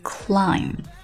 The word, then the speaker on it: climb: I would climb. Would 是虚爆破，climb 后面这个 b 是不发音的。I would climb Everest.